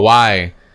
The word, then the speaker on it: Kawhi: said, we need the how do you say his name correctly? – Kyrie? – Kawhi. Oh. Kawhi.